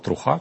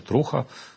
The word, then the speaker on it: Труха: Щодо свободи слова, я тут не буду вже казати про кейс Еспресо, але скажу про інший кейс, це так званий телеграм-канал Труха, чи Труха, як вони наполягають з наголосом.